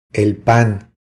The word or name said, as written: El pan